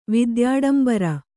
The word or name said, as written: ವಿದ್ಯಾಡಂಬರ